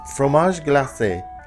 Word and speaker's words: fromage glacé: Did I say that right? – Fromage glacé, I believe, is the pronunciation. That's how I would say it.